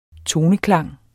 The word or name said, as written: toneklang